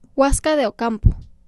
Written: Huasca de Ocampo

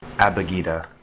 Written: Abugida

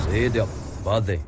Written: Seediq Bale

